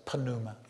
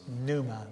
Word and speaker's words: pneuma: You can almost hear it in the Greek, pneuma. I hear some people pronounce it pneuma. It's pneuma. The P is silent, or the pi is silent.